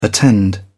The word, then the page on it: attend: British English